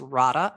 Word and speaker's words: Rada: So if you want to find me on LinkedIn, it's Rada, R-A-D-A. And my last name is Yovovich. I'll spell it Y-O-V as in Victor, O-V as in Victor, I-C-H.